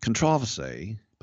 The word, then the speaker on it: controversy: Speaking of controversy, or as they say in England, controversy, put the emphasis on the second syllable.